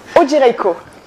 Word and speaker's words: Odzierejko: Alors, O-D-Z-I-E-R-E-J-K-O. Donc, Odzierejko.